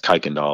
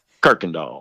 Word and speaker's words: Kirkendall: Well, it's probably supposed to be pronounced a different way, but that's all those names come from places around where I live in Brevard, mm-hmm. – and it's probably pronounced Kirkendall or something, but all the locals say Kirkendall.